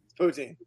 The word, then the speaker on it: poutine: Poutine.